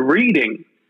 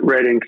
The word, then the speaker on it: Reading: When I first got there, I was like, "Oh, I'm going to Reading," Yeah. – But no, they pronounce it Reading.